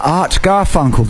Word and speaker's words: Art Garfunkel: It's Mr. Art Garfunkel.